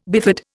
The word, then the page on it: bifid: Indian